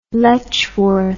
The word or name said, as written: letchworth